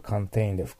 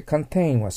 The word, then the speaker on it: contain: ね、コンテナから想像できればコンテインで含むというのがわかります。だから、コンテインじゃなくて、カンテインですけどね、発音がね。はい。そして、カンテインは制限内に保持するから、抑制するという意味もある。